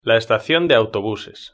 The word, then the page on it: la estacion de autobuses: Spain Spanish